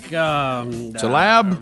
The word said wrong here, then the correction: Tlaib: Rashida Tlaib. – Tlaib. Is there some silent letters here? – Tlaib.